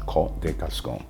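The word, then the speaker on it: Côtes de Gascogne: And it's from the Côte de Gascogne region, Côte de Gascogne. It's spelled C-O-T-E-S, de Gascogne.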